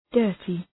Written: dirty

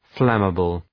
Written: flammable

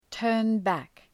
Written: turn back

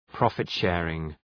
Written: profit sharing

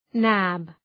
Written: nab

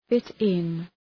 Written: fit-in

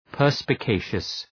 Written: perspicacious

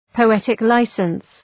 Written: poetic licence

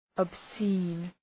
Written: obscene

